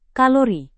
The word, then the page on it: kalori: Indonesian